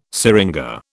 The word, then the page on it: syringa: British English